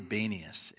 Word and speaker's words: Banias: It is modern day, I'm going to mispronounce it, I think it's Banias, that's the one. Modern day Banias is where this takes place, Caesarea Philippi in that early centuries there.